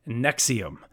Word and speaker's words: NXIVM: I think it's going to be 4 episodes when it's all said and done, about the self-improvement group / I'm going to say cult, Nexium. but it's spelled a little bit different. It's NXIVM.